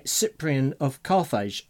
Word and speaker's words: Cyprian of Carthage: The Lord's Prayer was given that title by Cyprian of Carthage. Have I said that right? – Yes, writing around AD 252.